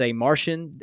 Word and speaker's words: Marcion: So Marcion, some have pronounced as a Martian.